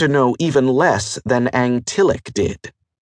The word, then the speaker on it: Ngtyllik: It was spelled N-G-T-Y-L-L-I-K and pronounced in a way not possible for most people with only one tongue, but Angtillic was how an Alendin would say it.